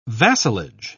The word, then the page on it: vassalage: British English